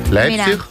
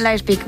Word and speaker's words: Leipzig: Leipzig. ¿Lo he dicho bien? – Qué importante saber idiomas. – Tengo aquí a mi amigo que me lo dice. ¿Cómo se dice? – Leipzig. – Leipzig. ¿Ves? – Leipzig, muy bien.